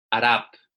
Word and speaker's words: Arap: Arap.